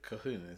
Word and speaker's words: Colquhoun: Cahoon, isn't it? – Is that how you pronounce it? – Yeah, Colquhoun.